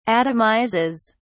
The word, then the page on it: atomizes: British English